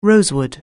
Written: rosewood

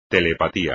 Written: telepatía

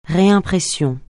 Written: réimpression

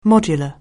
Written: modular

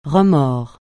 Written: remords

